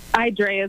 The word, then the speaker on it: Idrea: Am I pronouncing that right, Idrea? I'll have to teach Kelly how to phonetically spell, but is it Idrea or Idrea? – Idrea is correct.